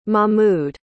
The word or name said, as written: mahmud